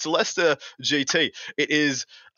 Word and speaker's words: Leicester: First beautiful event for you guys is the Leicester GT, as I'm heard it's called. It's not the Leicester, as it would be phonetically. – Oh, you did it. You actually did it right.